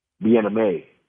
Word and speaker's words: Bien-Aime: Bien-Aime.